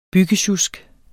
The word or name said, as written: BYGGESJUSK